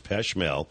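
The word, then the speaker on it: Pech Merle: It's PECHMERLE. All right, but it's pronounced Peshmel.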